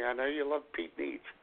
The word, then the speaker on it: Nitschke: Do we have any more good advice from Pete Nitschke? – Pete Nitsch. Yes, we do. – Oh, Nitsch. Is that how you pronounce it? – Yeah.